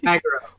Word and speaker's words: Magro: Magro.